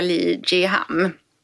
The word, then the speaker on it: Lee Jiham: Jag är blown away och den här lilla hörluren, det är ju alltså Ion Enhancer från koreanska Lee Jiham. – Ja, bra. Nu uttalade du rätt.